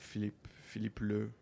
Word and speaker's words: Philippe Leu: It was a very large piece that got tattooed by a man called Philippe Leu, how it's pronounced in French,